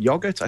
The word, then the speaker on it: Joget: We'll have Michael Yap, who's coming on to talk about yogurt. I think you pronounced that. It's J-O-G. I can't even say the letters. J-O-G-E-T. Yogurt, I'm going to pronounce that.